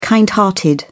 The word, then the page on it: kind-hearted: British English